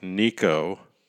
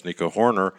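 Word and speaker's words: Nico Hoerner: Yes, it rhymes with Rico, and that would be Rico Petroselli, Rico Cardi, and Rico Crime if you're a lawyer in the strike force. But anyway, Rico, that rhymes with Nico, Nico the shortstop that took the place of all those other injured Cubs, Nico Horner.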